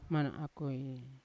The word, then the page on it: mana akoi: Yawa